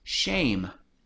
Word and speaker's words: Shem: Now Shem is Strong's Concordance number 8035, and his name is pronounced Shame. Now 8035 basically just says he's a son of Noach.